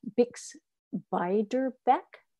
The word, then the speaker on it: Beiderbecke: bix beiderbecke am i pronouncing that Yep, you got it.